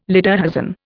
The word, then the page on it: lederhosen: Indian